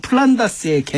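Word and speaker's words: Flanders: 이 플랑드르가 영국식 발음으로 하면 플란다스의 개인데요.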